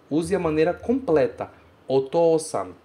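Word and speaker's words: Otosan (お父さん): E ela se pronuncia Otosan.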